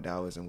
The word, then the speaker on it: Taoism: It's, well, mainly people spell it T-A-O, but it's pronounced Taoism, and, you know, it's created by Lao Tzu, L-A-O-T-Z-U, and it just preaches on the concept of the Force, which is energy, life force energy.